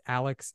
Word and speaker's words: Aleks: My name is spelled kind of funny. It's Aleks A L E K S, not A L E X.